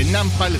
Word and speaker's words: Nampal: Nampal, así como suena, se escribe Nampal, suena aquí en El Bombardeo, en Rock and Pop.